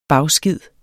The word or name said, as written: bagskid